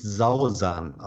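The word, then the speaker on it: Sawsan: Sausan, entschuldige, sie spricht sich Sausan aus. – Nee, denn du, sie hat mich im, im Gespräch mehrmals darauf hingewiesen, wie man ihren Namen ausspricht. Safsan.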